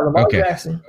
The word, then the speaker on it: Lamar Jackson: And then how do you figure this out with Lamar Jackson there? I said it right. I always forget, right? Is it Lamar Jackson, right? – Yeah, Lamar Jackson.